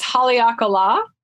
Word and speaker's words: Haleakala: So it's Haleakala.